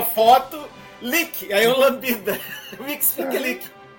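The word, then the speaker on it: Mxyzptlk: Então, foi daí que eu tirei a minha pronúncia, que eu acho que é a canônica do... Mitch Spiklick, que é da série Lois e Clark.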